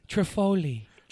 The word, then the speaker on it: Trifogli: The G is silent. – Oh, it's Trifogli.